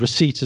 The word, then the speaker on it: receipt: And receipt as well. That's another one. – Yep. P, silent P.